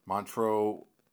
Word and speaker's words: Montreux: How do you pronounce it? Monterey? – Montreux, Switzerland.